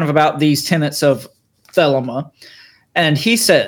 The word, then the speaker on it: Thelema: So in addition to all that, Crowley was also the founder of a spiritual movement known as the Church of either Thelema or Thelema. I've heard it pronounced both ways. I'm going to pronounce it Thelema.